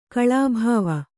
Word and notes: ಕಲ್ಬೋವು: kalbōvu

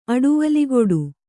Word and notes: ಅಡುವಲಿಗೊಡು: kannada

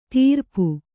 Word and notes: ತಿರ್ಪು: Kannada

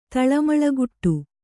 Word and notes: ತಳಮಳಗುಡು: Kannada

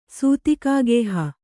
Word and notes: ಸೂತಿಕಾಗೇಹ: Kannada

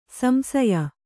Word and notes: ಸಂಶಯ: samśaya

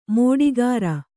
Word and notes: ಮಾಟಗಾರ: māṭagāra

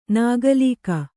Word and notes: ನಗಲಿಕ: kannada; nagalika

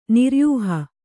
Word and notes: ನಿರ್ಯೂಹ: niryūha

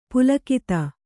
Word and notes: ಪುಲಕಿತ: Kannada

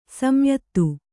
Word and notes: ಸಂಯುತ: kannada

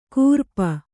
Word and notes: ಕೂರ್ಪ: kūrpa; Kannada

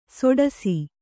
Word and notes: ಸೊಡಸಿ: soḍasi

